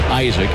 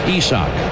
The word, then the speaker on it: Isaac: First name looks like it'll be pronounced Isaac, I-S-A-A-C. Pronounced Isak, Isak Paredes.